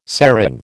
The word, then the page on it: serang: American English